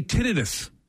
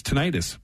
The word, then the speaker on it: tinnitus: It's tinnitus. But right. – It's actually tinnitus. Tinnitus? Tinnitus? Yeah.